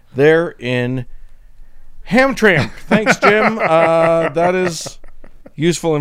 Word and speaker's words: Hamtramck: But Jim has written in to say, in case you need to cover events there again, the city of Hamtramck is pronounced as if there is a vowel in the last syllable, as in Hamtramck, with emphasis on the middle syllable.